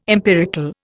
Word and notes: empirical: Indian

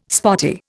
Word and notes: spotty: Indian

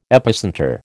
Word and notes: epicentre: American English

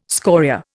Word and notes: scoria: Indian

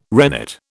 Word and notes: rennet: British English

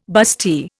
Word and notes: bustee: Indian

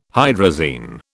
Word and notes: hydrazine: British English

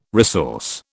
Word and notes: resource: British English

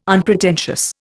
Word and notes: unpretentious: Indian

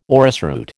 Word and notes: orrisroot: American English

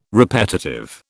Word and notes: repetitive: British English

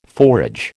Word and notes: forage: American English

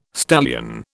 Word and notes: stallion: British English

